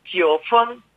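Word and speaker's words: 지오펀 (纠纷): 우리 그 한자, 분개할 때 규하고, 분쟁할 때 분을 또 규분이라는 한자를 쓰는데, 발음은 둘 다 일성입니다. 지오펀.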